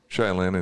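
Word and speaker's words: Shai Linne: Shay Lynn and Tedeschi.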